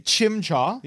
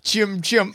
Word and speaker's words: Chimchar: Chimchar. – Yeah. – Yep. – I used to call Chim Chim. And I never thought why I was saying that because that is not how it's written.